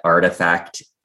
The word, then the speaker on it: RTFKT: I'm never exactly sure how to say that. Artifact, I believe, is how it is sort of said. It's spelled out RTFKT.